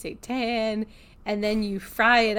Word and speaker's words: seitan: And that brings us to the seitan chicken.